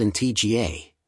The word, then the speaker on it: Antigua: Antigua, pronounced Antigua, and Barbuda, Barbuda, is located in the heart of the Caribbean Sea.